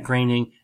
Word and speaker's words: Groening: and he said Matt Groening, and then in parentheses he said, rhymes with raining. So it was, that's the correct pronunciation.